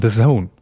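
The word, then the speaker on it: DAZN: Und irgendwann lief, glaube ich, mal der erste Spot im Fernsehen und dann wurde es halt irgendwann mal ausgesprochen, The Zone.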